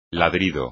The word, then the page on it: ladrido: espagnol